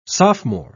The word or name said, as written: sophomore